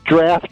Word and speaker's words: draught: It's spelled D-R-A-U-G-H-T, huh? – Right, but it's pronounced D-R-A-F-T.